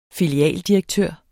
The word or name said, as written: filialdirektør